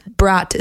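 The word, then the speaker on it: брат: Brat is how you say brother in Russian.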